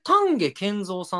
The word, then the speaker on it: 丹下健三: たこれ丹下健三さんって読むんですかね。 – ですね、多分そう。